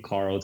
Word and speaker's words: Carl: My Twitter handle is just my name, Carl Zha. Carl spelled with a C, C-A-R-L. And my last name, Z-H-A.